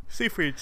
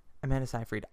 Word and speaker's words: Seyfried: Seyfried. – Amanda Seyfried. – Seyfried. Seyfried. – Amanda Seyfried. I think I say it with an S, but like with a very soft S.